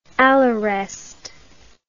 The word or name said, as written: allerest